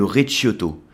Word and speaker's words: Recioto: Et ça c'est ce qu'on appelle le recioto. Alors ça s'écrit R-E-C-I-O-T-O. o t o le Recioto, Recioto.